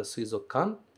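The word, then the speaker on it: suizokan (水族館): Então, só dando esse cuidado aqui na palavra suizokan, que eu acabei de pronunciar como eu falei agora, né? Suizokan, sem falar kukan, mas juntando os dois.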